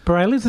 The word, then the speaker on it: Bareilles: Bareilles, is that how you say it? – Yeah, I think that's how you say it.